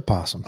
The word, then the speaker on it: opossum: They do get opossums, though. – Not called an opossum, just a possum. – Opossum. – O is silent.